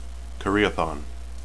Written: karaethon

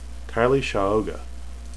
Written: keille

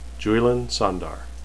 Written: juilin

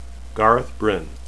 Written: bryne

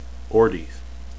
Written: ordeith

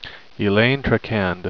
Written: elayne

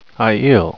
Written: aiel

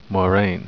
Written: moiraine

